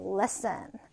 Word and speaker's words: listen: listen. 자, 발음해 볼까요? listen. listen. 자, 이때 T 묵음 처리하죠. 그냥 listen이 돼요.